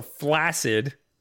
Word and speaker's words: flaccid: Also, flaccid is pronounced flaccid. – By who? – By the dictionary. Like, it's supposed to be pronounced flaccid, but nobody pronounces it like that. They say flaccid.